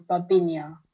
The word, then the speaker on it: Babyn Yar: And the Russian pronunciation is Babi Yar. The Ukrainian pronunciation is Babyn Yar.